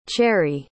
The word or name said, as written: cherrie